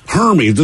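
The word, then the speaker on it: Hermès: Okay, well, Hermes rhymes with, you know. – What? – Herpes. – That's close. – I mean, it's close. – Hermes. – Hermes. It's closer to like Burmese, like a Hermes python.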